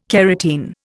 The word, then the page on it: carotene: Indian